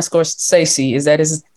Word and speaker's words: Scorsese: I agree with you, and also Martin Scorsese,